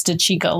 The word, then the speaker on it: DeCicco: exactly the same as this guy because we were like, I don't know how to pronounce it. And Henry's like, oh, it's DeCicco.